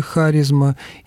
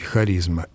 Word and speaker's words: харизма: Ну, кстати, вот орфоэпические словарь русского языка под редакцией Ирины Леонидовны Резниченко указывает, что это слово может произноситься двояко и с ударением на третьем от конца слоге харизма и посерединке харизма. Эти варианты указаны как равноправные.